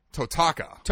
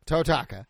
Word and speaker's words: Totaka: The composer is Kazumi Tokata. – Kazumi Tokata. – Oh, wait, nope. Hang on, I said that wrong. Kazumi Totaka. – Totaka.